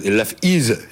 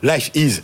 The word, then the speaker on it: Life Is: Oui, il fallait prononcer Life As, Life Is. J'ai du mal avec l'anglais parfois. Life Is.